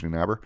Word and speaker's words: Neunaber: My name is Paige Neunaber. You can follow me on Facebook. You can follow, and under my name, it's spelled like it sounds.